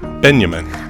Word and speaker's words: Binyamin: And that's spelled B-I-N-Y-A-M-I-N. Benjamin.